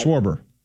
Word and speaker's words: Schwarber: Get his name right. – Schwarber. – Yes.